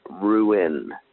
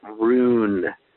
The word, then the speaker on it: ruin: Dee, you seriously need to learn how to say the word ruin. R U I N, not rune. That's that's R U N E or R O O N T.